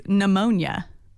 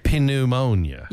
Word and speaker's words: pneumonia: Okay, another one is pneumonia, because the P is silent. – Yeah, pneumonia.